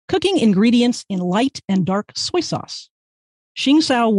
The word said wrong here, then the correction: Shaoxing: wine, pardon me, I pronounced that, Shaoxing wine. Pardon me, to everybody who speaks Chinese and is laughing at me now, wine, sugar, and spices like star anise and Sichuan peppercorns.